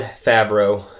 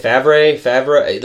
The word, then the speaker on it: Favreau: He pronounces it Jon Favreau. Because everybody's, how have you been saying it? – I've been saying it Favreau.